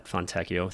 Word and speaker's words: Fontecchio: You presumably have Fontecchio. Thank you for the listener who reached out and told me that I was not mispronouncing his name, though I did mispronounce Prashida.